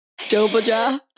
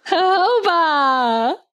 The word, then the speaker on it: jojoba: Jobaja. – Jojoba.